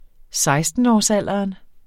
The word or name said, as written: sekstenårsalderen